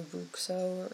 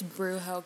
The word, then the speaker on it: Bruxo: So, also according to Pottermore, Libatius Borage was South American and attended the Brazilian school, Castelo Bruxo, or however you say it. – I think it's Brujo, because I think their accents are silent.